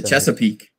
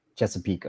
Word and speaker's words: Chesapeake: In the Chesapeake. – Chesapeake.